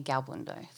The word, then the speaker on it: Galbuyn: You can call me Galbundo. So G-A-L-B-U-Y-N.